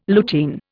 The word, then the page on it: lutein: Indian